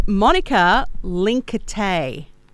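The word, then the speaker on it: Monika Linkytė: Would you like me to tell you how it's said? Yes, please, okay. Please. – It's Monika Linkate. Yeah. – Yeah. Okay, no, I didn't get that. I was way off.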